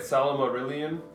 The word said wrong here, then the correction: Silmarillion: Silmarillion. – I can never say it.